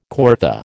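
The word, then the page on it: coryphée: American English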